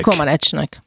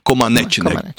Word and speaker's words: Comăneci: Egyáltalán, Zsuzsa, hogy ejtik a románok a nevét? Comăneci-nek vagy Comăneci-nek? – Comăneci-nek. – Comăneci-nek.